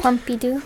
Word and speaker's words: Pompidou: So when you look at I don't know, is it Pompidou? How you pronounce it? Mm-hmm. Pompidou.